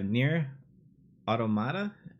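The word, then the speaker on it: NieR: Automata: So you have Nier Automata.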